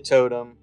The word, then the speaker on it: Tote'm: How was this spelled, by the way? – TOTE'M. – Okay, so it's like tote.